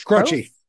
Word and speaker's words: Croce: Is it Croce? I never know how to say his name. Croach? – Crouchy, Croce? – crouchy. – Croachy.